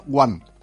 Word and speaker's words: Joan: Donat aleshores que el nom de Joan es pronunciava Guan.